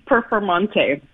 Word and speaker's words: Performante: How are they pronouncing this? Urus Performante? – Performante.